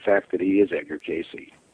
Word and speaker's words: Cayce: I want you to Google Wynn Free, which is W-Y-N-N space F-R-E-E. And then I want you to Google Edgar Casey, which is E-D-G-A-R space C-A-Y-C-E. Now, it's pronounced Casey, even though it looks like C-A-Y-C-E.